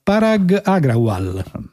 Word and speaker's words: Parag Agrawal: Allora il nuovo si chiama Parag Agrawal.